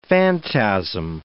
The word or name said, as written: phantasm